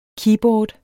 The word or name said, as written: KEYBOARDS